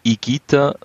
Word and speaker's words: Higuita: Erst Higuita, weil das H ist stumm und das G-U spricht man als G aus.